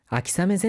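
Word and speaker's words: 아키사메 (秋雨): 아키사메 젠센모 야또 쇼멧시다미다이데스요. – 자, 여기 비우자를요 아메가 아니라 사메라고 읽습니다.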